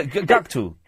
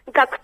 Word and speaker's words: Gackt: Oh, here we go, yeah, Gakuto. – Gakuto, yeah. – Okay. – G-A-C-K-T. And also, well, that's, yeah. – I'm going to check Gakuto okay.